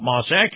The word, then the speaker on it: Maus: Now, Moss is spelled M-A-U-S, and Acura competes very favorably with other luxury brands.